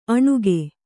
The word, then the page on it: ಅಣಕ: aṇaka